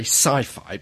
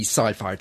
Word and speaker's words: Syfy: The Syfy channel, we'd like to say sci-fi, but it's spelt Syfy because that's how it is, but it's actually the sci-fi channel.